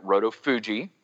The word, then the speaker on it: Roto Fuji: So to get to the pronunciation, we've always pronounced it Roto Fuji because it's named after Fuji, the dog, whose name is spelled with a G because our veterinarian is apparently horrible at spelling.